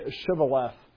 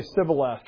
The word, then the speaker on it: Shibboleth: If he said no, then they would say to him, "Then say Shibboleth." And he would say Sibboleth, for he could not pronounce it right.